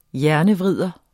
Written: hjernevrider

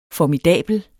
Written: formidabel

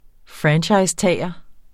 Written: franchisetager

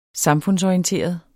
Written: samfundsorienteret

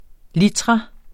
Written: litra

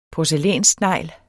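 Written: porcelænssnegl

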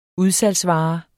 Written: udsalgsvare

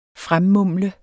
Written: fremmumle

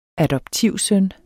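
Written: ADOPTIVSØNNER

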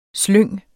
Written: slyng

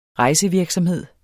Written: rejsevirksomhed

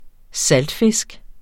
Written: saltfisk

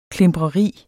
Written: klimpreri

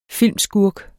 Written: filmskurk

